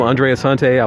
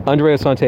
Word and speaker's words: Andreas Hunte: To find me personally, at my first and middle name, Andreas Hunte. I like it because it rhymes. A-N-D-R-E. My personal accounts on every platform. It's Instagram, Snapchat, YouTube, whatever. Andreas Hunte, A-N-D-R-E-A-S-U-N-T-E.